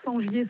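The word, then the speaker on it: Sengier: Sur mon nom, Sangier Sandrine.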